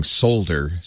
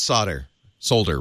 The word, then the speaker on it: solder: We don't do single payer healthcare, and we pronounce solder wrong. We're good at all three of those. All right. I didn't know. Okay, this just shows my ignorance. I didn't know that it wasn't pronounced solder outside of the United States.